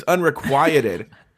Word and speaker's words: unrequited: And he was like, yeah, you just pronounced it wrong, but you got it right. – He went like, ugh, it's unrequited.